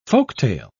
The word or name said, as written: folktale